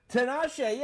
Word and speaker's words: Tinashe: Is it Tinashe? – Tinashe, yeah.